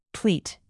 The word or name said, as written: pleat